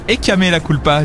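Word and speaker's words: Échame la culpa: On se quitte avec euh, Luis Fonsi et Demi Lovato pour euh, écamer la culpa.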